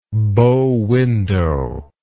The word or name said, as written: bow-windowed